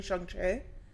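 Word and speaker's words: Shang-Chi: I, I was looking at a TikTok earlier where she was saying that because of the way it's spelled, it's actually Shungche.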